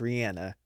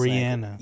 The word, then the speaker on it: Rihanna: The reason why I would say Rihanna, or actually I saw an interview where there was like, her name is actually pronounced Rihanna. And I was Rihanna.